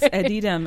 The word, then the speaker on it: Edidem: And that track's called, forgive me if I say this wrong, but Edidem. – You said it quite well. – Was I close? – You were quite okay. close. Edidem. – Edidem, okay.